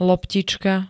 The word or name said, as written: loptička